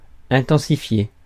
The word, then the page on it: intensifier: France; [ɛ̃.tɑ̃.si.fje]